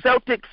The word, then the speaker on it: Celtic: Well, it's spelled like that, C-E-L-T-I-C. But it's not the Boston Celtics. I mean, it's not the Celtics as in the Boston Celtics. There, it's pronounced the Celtic.